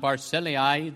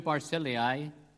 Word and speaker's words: Barzillai: His name is pronounced in various ways. I hear it pronounced in different manners. I have to confess, I don't know what the correct pronunciation of the Hebrew of the word is, but we will call him Barzillai this morning. That is the most common pronunciation of it, I understand.